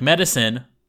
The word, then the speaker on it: Medasin: So, like medicine, like it's pronounced medicine, I guess, but it's spelt M-E-D-A-S-I-N, medicine.